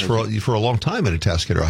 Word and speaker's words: Atascadero: He is the director and the president of We Are Atascadero Pride.